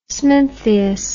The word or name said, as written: smintheus